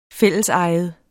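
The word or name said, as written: fællesejet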